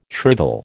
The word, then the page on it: shrivel: American English